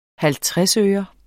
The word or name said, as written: halvtredsøre